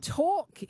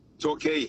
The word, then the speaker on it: Torquay: Torquay. – Torquay. – All the way back to Torquay. Yeah. – It's spelt Talk on my screen, like motor talk. Talk, but I thought, oh, it might be Torquay, but okay.